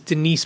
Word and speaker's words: Denice: Her name is Denise Brown. But Denise is spelled, at least the way she spells it, is D E N I C E. So it sounds like Dennis.